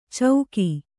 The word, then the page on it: ಚೌಕಿ: cauki